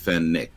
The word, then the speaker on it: Fenwick: And this was followed by a second st- uh, where they accused the National Church offices and Bishop Fenwick of being, it's spelled Fenwick, but it's pronounced Fennick. I think you're I'm right in saying that. – Yeah, you Fennick.